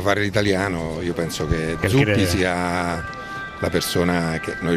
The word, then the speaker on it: Zuppi: Zuppi.